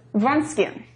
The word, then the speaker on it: Wronskian: Wronskian.